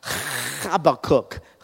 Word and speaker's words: Habakkuk: It's called Habakkuk. It's actually in Hebrew pronounced Habakkuk, Habakkuk.